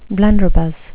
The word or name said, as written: blunderbuss